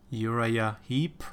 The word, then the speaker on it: Uriah Heep: Uriah Heep.